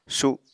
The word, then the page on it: su7: Apatani